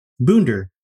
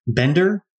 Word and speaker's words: Boender: Dr. Adam Boender, B-O-E-N-D-E-R. – I've always pronounced your name. I've said Bodner. – Yeah. You know, I get Bender, Bowender.